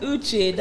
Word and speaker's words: Uche: This is not your regular Uche. – At Uche, that is UTCHAAY.